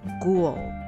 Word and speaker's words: ghoul: 所以特别注意一下，这个 H 是没有发音的哦。 Ghoul，我们来看一下例句。